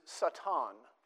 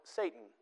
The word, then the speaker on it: Satan: The word there in the Hebrew is Satan. We give it a different pronunciation. We call it Satan.